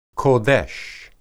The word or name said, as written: qodesh